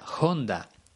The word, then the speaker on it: Honda: pues Honda en realidad se pronuncia Honda.